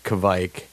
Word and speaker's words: Kveik: And his father was sitting at the bar and said Kveik. And I was like, yeah, you said it right.